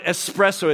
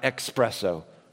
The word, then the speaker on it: espresso: It is espresso, not expresso.